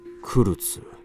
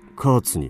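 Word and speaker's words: Kurtz: クルツ、どうやら本名ではないらしい。ドイツ語でチビという意味だ。我々イギリス人の発音ではカーツになる。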